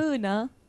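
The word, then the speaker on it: până: Dar na conoscut o pina o pena o come si dice pina? – Puna.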